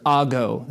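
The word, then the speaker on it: ago (ἄγω): So the word led in Greek is the word ago. That's A-G-O, long O, ago.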